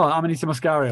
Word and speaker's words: Amanita muscaria: I seen that video you did with the Anamaska. I'm going to say it wrong. The fucking mushroom. The red and white one. – Oh, Amanita muscaria.